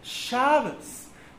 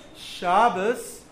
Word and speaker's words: Shabbos: Ashkenazic Jews will put the emphasis on the beginning of the word. Sephardic Jews put the emphasis on the end of the word. So for Ashkenazim, it's Shabbos.